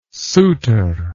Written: souter

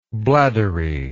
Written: bladdery

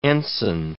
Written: ensign

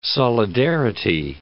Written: solidarity

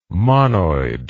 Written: monoid

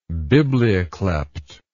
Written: biblioklept

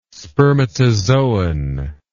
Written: spermatozoon

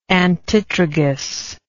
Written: antitragus

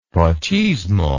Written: pochismo

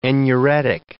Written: enuretic

